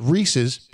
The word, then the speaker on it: Reese's: Reese was a person, apostrophe S. – And they were his. – And they were his pieces. Reese's Pieces. That is the proper pronunciation, yes.